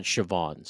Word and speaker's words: Siobhan: Or sorry, they pronounce that Siobhan.